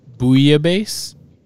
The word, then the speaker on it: bouillabaisse: you pronounced bouillabaisse correctly. The L's were not pronounced, so good job, Rogge. Those L's, yeah, get rid of them.